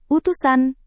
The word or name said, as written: utusan